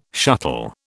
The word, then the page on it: shuttle: British English